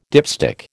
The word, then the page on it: dipstick: American English